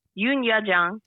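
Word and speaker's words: Youn Yuh-jung: Yoon Yu Jung.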